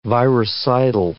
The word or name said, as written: viricidal